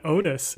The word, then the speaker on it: Otis: And his name rhymes with coitus. – Oh, goodness. I think it's one Otis, who we just met not 3 scenes ago.